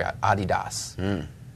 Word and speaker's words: Adidas: Maybe they'll be able to tackle this when they come on, but Adidas.